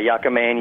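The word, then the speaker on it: yakamein: I was just looking at that pronunciation the other day, actually, coincidentally, and that's one of the pronunciations. There's so many of them. So, yeah, yakamine, yakamine, yakamine.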